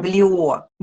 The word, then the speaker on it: Блио: И можно я чуть-чуть тебя поправлю? Элен Блио. – Блио, да, прости, пожалуйста. – Ударение на последнее.